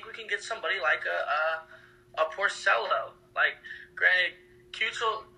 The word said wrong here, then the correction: Keuchel: I can't pronounce his last name on the board. Keuchel, God, there it is.